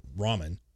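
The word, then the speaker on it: Raman: According to the reports, the $400 million Blue Ramon, or Ramen, is that how you say it? – Yeah, like Top Ramen. Top you got ramen.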